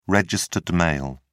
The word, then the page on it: registered mail: American English